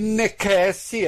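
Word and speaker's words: necesse: teria dito: navigare necessi est, necessi se escreve com C, mas se lê K, muito provavelmente.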